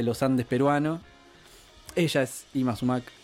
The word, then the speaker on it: Yma Sumac: Ima Sumac. Ima es el nombre. – Ima Sumac. – Se escribe con Yma Sumac.